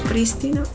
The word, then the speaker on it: prístinos: Prístinos. Prístinos. Es prístinos, con acento en la primera I.